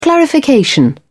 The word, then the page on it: clarification: British English